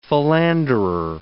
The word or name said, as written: philanderer